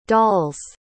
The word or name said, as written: doll's